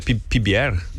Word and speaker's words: PBR: Como é que fala? É PBR? – Isso.